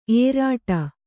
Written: ಏರಿಡು

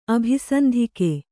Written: ಅಭಿಸಂಧಿಕೆ

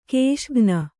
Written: ಘಸಕ್ಕನೆ

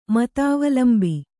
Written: ಮತಾವಲಂಬಿ

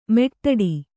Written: ಮೆಟ್ಟಡಿ

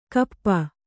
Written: ಕೋಪಿ

